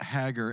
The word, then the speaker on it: Hager: Um, today, though, I'm sharing the stage with a really good friend of mine, uh, Lynette Hager. And I don't know if I actually say your last name right. It's Hager. – It's Hager. Hager. Like, hey, girl.